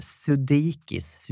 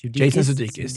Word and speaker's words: Sudeikis: Sudeikis. – Jason Sudeikis.